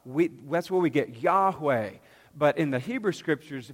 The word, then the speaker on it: Yahweh: So even though you saw Yod He Vav He, the four Hebrew characters, we pronounce Yahweh, you, you would say Adonai.